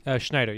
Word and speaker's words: Schneider: Schneider, yes.